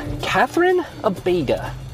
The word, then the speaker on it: Cathryn Abeyta: Cathryn Abeyta. Is that how you say that? – It sure. – Am I is. – Saying it right? – You sure are.